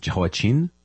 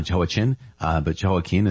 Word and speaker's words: Jehoiakim: Now, there was Jehoiakim with an M at the end, and Jehoiakim with an N at the end. I've also heard his name pronounced Jehoiachin with a kind of soft ch. But Jehoiakim is how I committed to saying it when we were reading 2 Kings and 2 Chronicles.